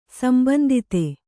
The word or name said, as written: ಸಂಬಂದಿüತ